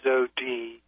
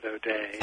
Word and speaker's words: O'Dea: James O.D., that's spelled O-D-E-A. Even though in Washington State they pronounce it O'Day.